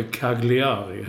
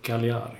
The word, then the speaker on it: Cagliari: Ja, men G och L blev inte Gagl, utan någonting annat. – Nej, precis. Och, precis som du säger inte Cagliari heller. Du säger Cagliari.